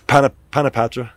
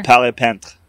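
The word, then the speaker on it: Paret-Peintre: Panapatra. Is that his name? – Sure. – Palais-Pent. Something like that. – Okay. – Yeah, Palais-Pent. It's like parrot pain tree in English.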